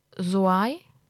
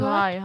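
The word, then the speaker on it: Zouaï: Ihr habt gerade Lolo Soai gehört. – Soai, hast du gesagt. – Genau. – Ja. – Also sehr viele Vokale, seht es mir nach, wenn die Aussprache nicht richtig ist, geschrieben Z-O-U-A und dann dieses I, wo dieser Querstrich oben als I-Pünktchen drauf ist.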